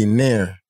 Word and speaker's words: Nare: How you pronounce it again? – N-A-R-E, Nair. Nair. I think it was Nair. – It's Nair. – It was N-A-R-E.